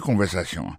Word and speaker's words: conversation: Coutez conversation. Mot conversation, accent tombé sur avant-dernier syllabe là.